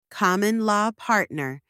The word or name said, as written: common-law partner